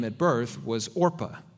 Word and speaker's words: Orpah: Uh, and people kept mispronouncing Orpah and saying Oprah,